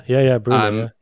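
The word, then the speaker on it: Bryne: Correct my pronunciation. It's Breena, is it? – Yeah, Breena.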